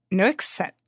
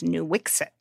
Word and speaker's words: Nuiqsut: This is a hard one. It is Nuiqsut. It's I actually, you know, have been trying very hard to pronounce it correctly. The official English pronunciation that the state of Alaska has is Nuiqsut.